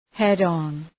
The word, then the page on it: head on: ‘hed,ɒn